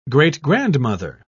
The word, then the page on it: great-grandmother: British English